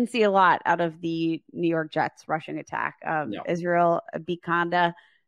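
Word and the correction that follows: Abanikanda: Do I say that right? – Abakanda. Yeah. – Abakanda. Okay. Sorry, Israel.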